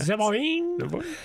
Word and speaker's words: Zeboim: Then the king of Sodom, the king of Gomorrah, the king of Admah, the king of Zeboim. That's how they pronounce it. It was Zeboim.